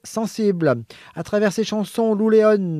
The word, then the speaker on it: Lou Léon: L-E-O-N, à l'espagnol, non? – Si tu le prononces Léon, oui. – Non, c'est lui qui m'a demandé de le prononcer comme ça. – Lou Léon. Oui, écoute. – Bon, voilà. – Léon en espagnol.